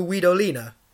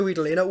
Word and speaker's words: Guidolin: Okay, it's spelt Guidolin. It's not how you say it. It's Francesco Guidolina.